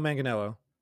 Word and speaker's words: Manganiello: Joe Manganiello.